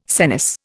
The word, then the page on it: senesce: Indian